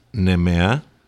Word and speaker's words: Nemea: Moscho Filero, it's Mantinia, Agio Rigitico, Nemea, Assyrtico, Santorini.